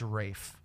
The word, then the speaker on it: Ralph: It's spelled Ralph, but it's Ray. – That's actually how you actually... No, it's Rafe. – Rafe. – Yeah, that's actually how you say Ralph.